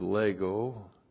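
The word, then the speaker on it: lego (λέγω): Here's the verb lego, L-E-G, long O, and this is the plural of the personal pronoun su, and this is H-U-M-E. E-I-S.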